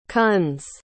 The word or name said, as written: Kunz